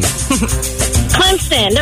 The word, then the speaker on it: Clemson: And do you pronounce it Clemson or Clempson? – Clemson. There's a P. – Uh-huh. – Yes. – There's no P in the spelling.